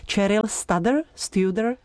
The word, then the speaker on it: Cheryl Studer: Vždycky u této americké sopranistky nevím přesnou výslovnost, tak doufám, že mě pomůžete. Cheryl Studer? – Já bych řekla, že to říkáš dobře, já bych to určitě neřekla tak hezky anglicky.